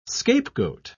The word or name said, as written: scapegoat